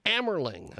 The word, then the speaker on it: Amerling: And around the world, the fantastic Dr. Richard Amerling is with us. I hope I'm getting that last name correct. Am I pronouncing your last name correct, Doctor? – That's close. It's Amerling. – Amerling.